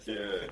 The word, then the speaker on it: sarong: With the emphasis on the second sarong.